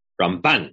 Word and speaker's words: Rambam: Yes, but first you have to pronounce it the way Shlomo Riskin tells us to. Rambam.